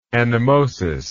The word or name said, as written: anemosis